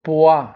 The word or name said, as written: poids